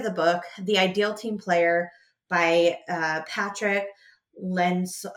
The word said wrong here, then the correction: Lencioni: Oh gosh, I'm going to butcher his name. It's Lencioni. It's How to Recognize and Cultivate the Three Essential Virtues.